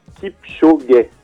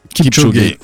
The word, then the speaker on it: Kipchoge: Et d'ailleurs, on me dit dans l'oreillette que ça, du coup, ça se dit Kipchoge. – Kipchoge.